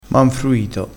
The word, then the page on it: manfruito: dialettale